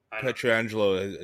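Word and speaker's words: Pietrangelo: Petrangelo is how I think you actually pronounce it.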